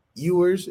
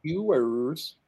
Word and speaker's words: Ewers: But man, it's so interesting in the quarterback world because a guy like Quinn Ewers, is that how you say it? – Yeah. – I mean, Ewers.